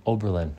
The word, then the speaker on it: Oberlin: Oberlin.